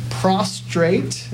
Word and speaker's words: prostrate: Um, prostrate is how you pronounce that. Be careful.